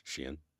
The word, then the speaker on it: Shein: I'm probably going to pronounce it incorrectly, but Shein. – Yeah, I think it's Shein.